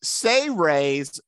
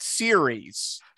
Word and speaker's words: Seres: And this is Say Raise, not series. – So it's spelled S-E-R-E-S, but he pronounces it Say Raise.